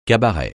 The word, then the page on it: cabaret: francais